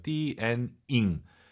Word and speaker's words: 电影: 근데 니은 들리면 안 돼요. 띠엔 잉, 잉, 잉입니다. 잉 여러분 꼭 기억하시고요. 띠엔 잉 연음 현상 조심하시기 바랍니다.